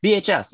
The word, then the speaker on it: VHUS: So you're the host of a podcast called the VHUS podcast. Am I saying that right? – VHS, yeah.